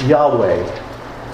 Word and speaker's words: Yahweh: The Hebrews wrote it YHWH, and it's pronounced Yahweh.